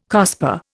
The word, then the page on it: COSPAR: Indian